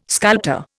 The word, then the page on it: sculptor: Indian